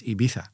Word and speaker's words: Ibiza: De officiële Spaanse uitspraak, en dat komt dus uit Madrid, is Ibiza.